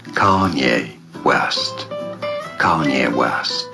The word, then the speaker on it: Kanye: Kanye. Kanye. – Kanye, finde ich, sagst du super. – Ich glaube, die Betonung ist auf der ersten Silbe.